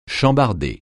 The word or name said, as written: chambarder